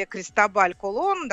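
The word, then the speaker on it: Cristóbal Colón: или Кристобаль Колон, да, собственно, как произносилось его имя,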